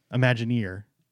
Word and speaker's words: Imagineer: Speaking of retirement, we'd like to congratulate the legendary Imagineer, emphasis on the ear, Joe Rohde, for an amazing 40-year career with Walt Disney Imagineering.